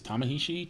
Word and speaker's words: Ta-Nehisi: Deadline reported that DC Films and Warner Brothers are developing an upcoming Superman feature film that will be written by author Ta-Nehisi. Did I say that right? – I think it's Ta-Nehisi.